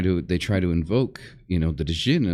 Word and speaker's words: djinn: Or the, and it's actually pronounced djinn, right? So it's D apostrophe J-I-N-N, djinn.